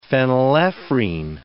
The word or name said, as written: phenylephrine